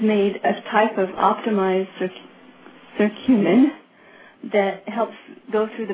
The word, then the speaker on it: curcumin: One of the things that I hear a lot about is curcumin.